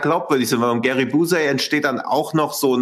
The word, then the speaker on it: Busey: Wie wird der eigentlich ausgesprochen? Und Gary Busey. Ja, Busey.